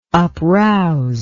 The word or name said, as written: uprouse